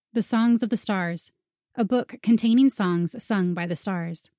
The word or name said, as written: The Songs of the Stars